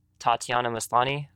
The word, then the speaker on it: Tatiana Maslany: So knowing that you're going to have that dual format and that the audio is actually narrated by Tatiana Maslany, I hope I'm pronouncing her name correctly. – Yep.